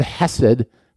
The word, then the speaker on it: chesed: God uses the Hebrew word chesed actually is how it's pronounced.